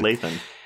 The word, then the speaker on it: Lathan: But again, like, there's something about the chemistry between these two actors that is really saying something, because Lathan is not in this enough to, I said her name wrong. – Good, Lathan. It's like Nathan, Lathan. But Lathan.